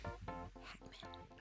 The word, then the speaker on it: Hacmon: But aside from that, he's one of my favorite people, the best person I know, my baby daddy, and my husband, Moses Hacmon.